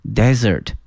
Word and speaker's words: desert: During the summer people use the plant to decorate desserts，好，那么出现了这个甜点啊，这个词叫 dessert， D-E-S-S-E-R-T， dessert，这个词啊和这个沙漠哈有点相似，沙漠的拼写呢是 D-E-S-E-R-T，叫做 desert， desert，那么甜点呢是 D-E-S-S-E-R-T 哈， dessert，它的发音呢不太一样啊，因此啊同学们能够区分出这个甜点和沙漠它的拼写和发音上的这个区别。